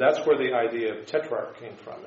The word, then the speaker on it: tetrarch: Remember, it's not tetriarch, it's tetrarch. We don't have that third syllable in there.